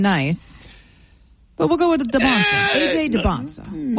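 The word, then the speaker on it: Dybantsa: I say DeBonsa, DeBonsa. – I know. Can we get one pronunciation, please? Because I've heard it both ways for 2 years. So DeBonsa. – We're going DeBonsa.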